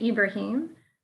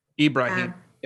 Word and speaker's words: Ibrahim: And they pronounce it Ibrahim. – Ibrahim. Yep, mm-hmm. That's right.